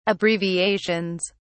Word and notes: abbreviations: British English

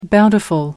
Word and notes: bountiful: American English

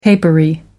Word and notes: papery: American English

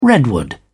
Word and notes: redwood: British English

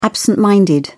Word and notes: absent minded: British English

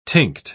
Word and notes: tinct: British English

